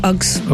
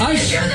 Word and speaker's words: Uggs: They're called what? Uggs. Ush. No, Uggs. Ush. Uggs. Ush. That's a funny name, Ush. – No, U-S-S-S. Ush. – No, U-G-G-S.